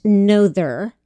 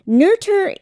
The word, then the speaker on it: Noether: Her name looks like it should be pronounced Noether, N-O-E-T-H-E-R, but it's actually pronounced Noether.